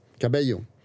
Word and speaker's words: Cabello: Cabello. LL, det blir liksom...